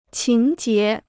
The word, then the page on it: 情结: qíng jié